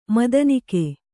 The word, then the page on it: ಮದನಕೈ: madana kai